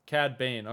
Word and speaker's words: Cad Bane: Cad Bane.